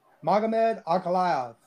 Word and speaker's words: Magomed Ankalaev: Magomed, how do you pronounce it? – Magomed Ankalaev. – Ankalaev, dude, you're just showing the fuck off.